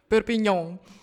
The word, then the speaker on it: Perpignan: Perpignan.